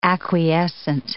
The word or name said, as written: acquiescent